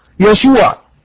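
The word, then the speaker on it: يشوع (ישוע): النقطه رقم اثنين، النقطة رقم اثنين، قلنا ان يسوع في العبرية تنطق يشوع.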